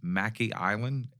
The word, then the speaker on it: Mackay Island: And I'm going to pronounce it as Mackay Island, M-A-C-K-A-Y.